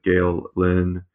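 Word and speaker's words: Gael Linn: Gail Lynn. I'm sure I'm mispronouncing that. I apologize. – No, you're not. Not this. That's perfectly pronounced.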